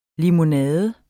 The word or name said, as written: limonade